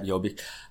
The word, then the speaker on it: Jobbik: is Jobbik. Pronounce that correct? Yeah, Jobbik.